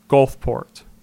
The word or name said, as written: Gulfport